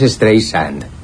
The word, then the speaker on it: Streisand: Ah, no, es Streisand. Sand.